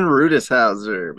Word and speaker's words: Rutishauser: I was going to try to pronounce your last name, Steven, but I'm just going to let that roll, and I'm going to let you do it because I don't want to butcher it too bad. – Steven Rutishauser, baby.